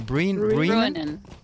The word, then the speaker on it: Bruinen: Do you pronounce it Brunnen or Brunnen? – Brunnen. – Brunnen. – Brunnen. – Brunnen. Brunnen. – Like Bruin.